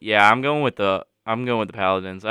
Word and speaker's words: Paladins: I think I'm going to have to go with the Palladians. Is that how you pronounce it? – I love that. I have heard to go to Furman. It is the Paladins.